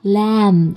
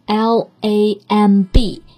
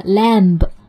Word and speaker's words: lamb: 那我们在这个地方一定要特别注意 lamb 的这个单词的发音，虽然它的拼写是。 L A M B，但是词尾的 b 其实不发音的，不要读成的 lamb，应该是 lamb。